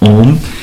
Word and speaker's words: Om: The first thing is, if you want to have this a complete experience of Om, it is good if you know how to pronounce it properly. Phonetically, that means the sounds which should be in there, are A, U, and M. A pronounced as A, U pronounced as U, and then M.